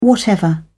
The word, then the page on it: whatever: British English